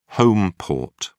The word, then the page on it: home port: British English